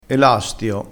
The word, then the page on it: elastio: dialettale